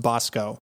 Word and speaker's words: Basco: It's spelled Basco, but it's pronounced Bosco. So I'm glad to be able to pronounce that correctly now.